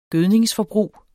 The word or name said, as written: gødningsforbrug